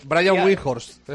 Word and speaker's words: Brian Windhorst: Brian Windhorst es el personaje. – Esa es la pronunciación correcta.